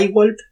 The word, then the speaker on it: iWalp: Y con respecto a la empresa, la plataforma educativa se llama iWalp, que se escribe IWALP, Nosotros estamos muy orgullosos de haberlo hecho.